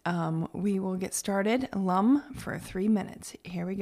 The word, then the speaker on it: Lam: So today, while we're singing Lum, it's just L A M, but it's pronounced Lum.